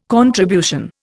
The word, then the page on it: contribution: Indian